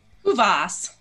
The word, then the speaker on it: Kuvaas: Kuvaas. – Kuvaas. That's what I wanted to make sure with those last three letters, people could maybe say it wrong.